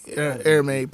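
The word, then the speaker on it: Hermès: Am I pronouncing that correctly? Hermes? – I think Hermes probably is the right way.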